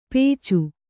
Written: ಪೇಚು

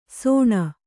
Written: ಸೋಣ

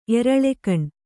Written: ಎರಳೆಗಣ್

